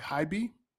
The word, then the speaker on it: HYBE: Hybe? Yes. Okay. – Yeah. – So you go on and you work for Hybe. Can you talk about that experience and what you did there? – Actually, like Hybe was the company that people start to think about who knows the U.S.